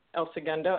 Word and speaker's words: El Segundo: Um, so Saul is has been on for the past four years uh, from El Segundo, and I hope I'm pronouncing that correctly.